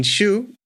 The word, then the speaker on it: Xu: It's Xu as in second tone. – Second tone, Xu. Okay. – Yeah. Shuangren Xu if you speak Chinese.